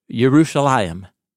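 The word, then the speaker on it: Jerusalem (ירושלים): So 2 Samuel chapter 5, verse 6, the king, David, and his men went to Jerusalem. That's how you say it in Hebrew, Jerusalem, to the Jebusites, the inhabitants of the land.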